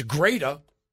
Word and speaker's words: Greta: And I said her name wrong. Sure, you look at it, it looks like Greta Thunberg, but mm-hmm. that's not how it's pronounced. – It's not Greta? – It's Greta. – Hmm. – And her last name is Thunberg.